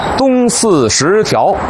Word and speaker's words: 东四十条: 还有今天呢咱们来到的这个地方东四十条，这是标准发音啊，但是您得琢磨琢磨这怎么断句呀。您肯定听过有人念成东四十条，其实啊应该念成东四十条，因为过去北京城啊从这个东四往北有 14条东西走向的胡同，就是东四头条到东四 14条。